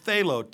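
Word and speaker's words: thelo (θέλω): The word will in the Greek is the word thelo, T-H-E-L long O. Thelo, will, thelo.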